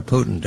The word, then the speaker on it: Putin: There's only one person, Vladimir Putin. Do I say that correctly? – I think you're absolutely correct.